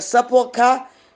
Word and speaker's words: sepulchre: So what is a sepulcher? Is spelled S-E-P-U-L-C-H-R-E. But it's called sepulcher.